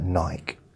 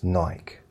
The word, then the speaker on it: Nike: It's like Nike, the sports thing, it's Nike. But I know in America they call it Nike, and they keep trying to get people to say Nike here, and I think the young people probably do.